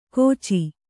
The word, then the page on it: ಗೋಚಿ: Kannada